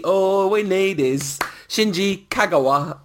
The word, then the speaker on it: Kagawa: There's no gao sound in it, so it is not Kagawa. And there's no emphasis in Japanese, apparently, or there's no emphasis in Kagawa anyway, so you just hit all the syllables equally. It's Kagawa, Kagawa, Kagawa.